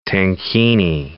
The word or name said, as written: tankini